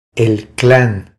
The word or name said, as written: El clan